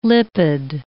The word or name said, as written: lipid